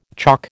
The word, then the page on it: chalk: American English